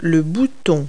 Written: button

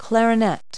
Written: clarinet

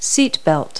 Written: seatbelt

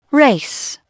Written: race

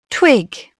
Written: twig

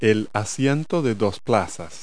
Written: dual seat

